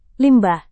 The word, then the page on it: limbah: indonesian